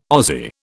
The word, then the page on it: Ozzie: British English